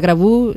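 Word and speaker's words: Graboo: Exacto, se pronuncia Grabu, se escribe Grabó con dos O's.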